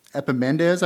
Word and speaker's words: Epimenides: It's Epimenides, I believe is how it's pronounced.